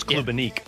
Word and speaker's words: Klubnik: Klubnik? Is that how you pronounce it? – It's Klubnik. Yeah.